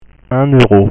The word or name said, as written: 1 euro